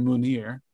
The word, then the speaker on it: Mounir: Munir Shuyar. – Better you than me, my friend. I couldn't have done it. – I'm very confident in Munir. M-O-U-N-I-R, and then Shuyar.